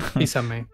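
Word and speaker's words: Fils-Aimé: Preguntan aquí, ¿Duck Bowser podrá llenar los zapatos de Reggie mm, Físeme? ¿Lo dije bien? – Físame. – Físame.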